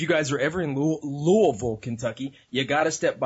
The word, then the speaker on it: Louisville: Not Louisville, not Louisville, Louisville. A lot of people don't get that right.